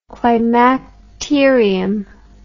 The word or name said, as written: climacterium